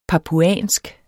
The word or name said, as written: papuansk